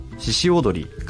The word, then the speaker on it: 鹿踊り: はい、ブランド名は漢字で鹿の角と書いて四角と読むのですが、これには 2 つ名前の由来がありまして、うん、1 つは私が 3 歳の頃からずっと続けている郷土芸能の獅子踊り、漢字では鹿踊りって書くんですけど、うん、読み方は獅子踊りって読みます、